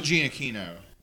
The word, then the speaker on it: Giacchino: How do you pronounce his fucking name? – Oh, Michael Giacchino.